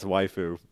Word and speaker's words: waifu: It's not waifu? Is it waifu? – Yeah, it's waifu. Like wife.